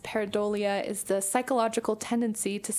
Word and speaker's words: pareidolia: Okay, this is a hard word to pronounce. Pareidolia? Pareidolia. Mm-hmm. That sounds right to me. Pareidolia.